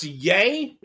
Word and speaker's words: Ye: He's like Y-E? Isn't that, is that pronounced Ye? – Yeah, I think it's Ye.